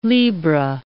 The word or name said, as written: libra